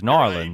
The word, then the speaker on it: New Orleans: the way people pronounce right. the city is Narlands. Right. So it, it actually like it's the beginning of the way you actually pronounce the word if you're a local.